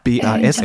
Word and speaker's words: BASF: das ist BSAF. Und ich glaube, wenn so eine Chemiefirma... – BASF, äh, glaube ich. – BSAF, habe ich das falsch gesagt.